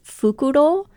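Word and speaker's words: fukurō: I also wondered if perhaps the joke is supposed to be that it's fukuro, which is how you say owl.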